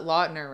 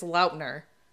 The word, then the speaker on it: Lautner: it's not Lautner, it's Lautner.